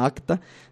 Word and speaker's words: Racta: Un grupo de puras mujeres que se llama Acta, se escribe con R, Racta, pero la R no sé por qué suena Acta.